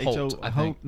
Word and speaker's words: Hoult: And I think Nicholas Hoult, is that how you say it? – Holt, Nicholas, H-O-U-L-T, right? – Yes.